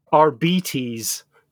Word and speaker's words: Arbites: Arbites.